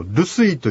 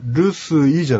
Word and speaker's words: ルスイ: ここで一つだけ注意していただきたいんですけども、ルスイという単語ですが、このイの発音は実は Y だけの発音なんですね。 – あ、ですからルスイじゃなくてルスイというようなちょっとイを軽く発音していただくと近い発音になります。